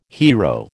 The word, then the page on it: hiero: American English